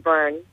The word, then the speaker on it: Byrne: And your last name is B-Y-R-N-E, right? – That's correct. It's pronounced Byrne. – Yeah, Byrne. B-Y-R-N-E.